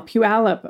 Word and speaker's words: Puyallup: Oh, Al, as in the name Al. Puyallup. Okay, it took me 5 years to be able to pronounce Puyallup.